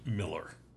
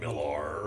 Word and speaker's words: Millar: Also, the comic writer's name is pronounced Miller. – Oh, okay. – I thought it was like Millar or something like that.